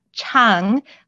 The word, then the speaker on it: Chang: So the second syllable is chang, like aha chang, not chang like bang.